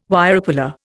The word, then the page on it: Wirepuller: Indian